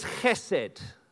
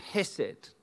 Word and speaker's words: chesed: The word is chesed. Chesed. The fancy version is hesed. But the Hebrew pronunciation is chesed, like you clearing your throat. So we'll say chesed, H-E-S-E-D.